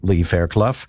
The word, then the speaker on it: Lee Fairclough: And so Navdeep Bains, just got a text, incidentally, correcting my pronunciation of Lee Fairclough's name. That's how we say it. Stephanie Smythe reminding me of that. So Lee Fairclough, Stephanie Bowman, Dylan Mirando, and Eric Lombardi.